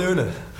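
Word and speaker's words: Åne: They give a tip. The A is pronounced like the O in Una. – Horse.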